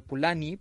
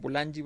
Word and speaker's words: Polanyi: Em, bueno, hay un argumento que es el argumento que, desarrolla formidablemente Carl eh, Polanyi, o en realidad el, el, apellido es Polani, pero lo conocemos todos como Polanyi porque tiene griega ahí y aquí la pronunciamos así, em, que es eh, una gran traducción de argumento, de uno de los argumentos de fondo de Marx.